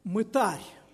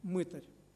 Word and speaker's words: мытарь: Ну, кстати, слово, которое мы произносим как «мытарь», в русском языке норма вообще-то «мытарь». Отсюда глагол «мытарствовать», да? Но в нашей среде прочно установилось ударение на первом слоге «мытарь».